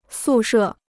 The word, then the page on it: 宿舍: sù shè